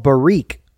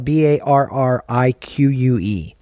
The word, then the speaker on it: Barrique: Uh, but it's called Barrique. It's spelled B A R R I Q U E.